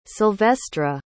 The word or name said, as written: Silvestra